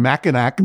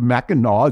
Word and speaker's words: Mackinac: You got so mad at me for saying Mackinac instead of Mackinaw.